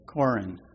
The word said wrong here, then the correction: Korah: Am I pronouncing that right, honey? Korin? You can look at it in your Bible. Korah. Yeah, Korah. Okay, there we go.